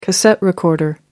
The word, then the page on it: cassette recorder: American English